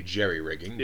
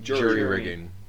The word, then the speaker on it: jury-rigging: An android who is gifted in linguistics, industrial equipment, similar to my boy Jimmy, and jury-rigging. I'm pretty sure that's supposed to be jerry-rigging. – It's not. That's always been the wrong way to say it, and everyone fucking says it wrong.